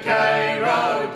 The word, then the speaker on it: Cairo: Cairo, I think it was Cairo, C-A-I-R-O, but they pronounce it Cairo.